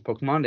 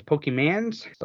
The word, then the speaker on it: Pokémon: I mean, they didn't even pronounce it as Pokemon. They always pronounced it as Pokemans.